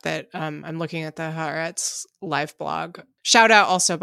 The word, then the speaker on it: Haaretz: Anyway, I'm going to say it correctly this time. The Haaretz live blog says that Netanyahu is set to give an address to the Israeli public on Wednesday evening, so shortly.